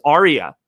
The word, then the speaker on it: Ariea: And his name is Aria. It's spelled A-R-I-E-A.